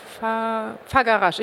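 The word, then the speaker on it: Făgăraș: Fagarasch ist das Gebirge oder Farag... Nee, Fagarasch. Ich glaube, erst das G und dann das R.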